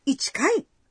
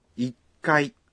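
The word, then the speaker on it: ikai (一階): Um é ichi, então ichikai? – Ah, quase. A resposta correta é ikai. – Ichikai é difícil de se pronunciar. Portanto, se diz... – Ikai.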